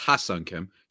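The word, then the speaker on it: Ha-seong Kim: Firstly, I want to just say before the Padres fans get mad at me and potentially you, it's Haesung Kim, just to pronounce it correctly.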